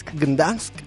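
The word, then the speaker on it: Gdańsk: Gdansk, Polonia.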